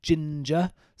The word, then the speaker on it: Jinjer: I listened to an album by a band by the name of Ginger. It's spelled J-I-N-J-E-R.